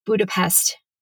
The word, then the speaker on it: Budapest: Budapest, tomato, in Hungarian. In Hungarian, the S is pronounced like a psht.